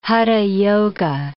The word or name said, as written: hatha yoga